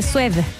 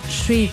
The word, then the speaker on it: Suede: Esto se escribe Suede. Hay gente que dice Suede, pero es Sweet, Sweet.